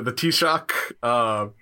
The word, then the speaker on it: Taoiseach: Okay, here's my attempt at pronunciation. The Taoiseach. – Yes, yeah, very good.